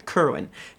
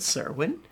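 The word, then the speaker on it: Qyburn: Is it Kerwin? Kerwin. How we pronounce Kerwin, which is nice. – Apparently. – Because I could never figure that one out. I always said Serwin.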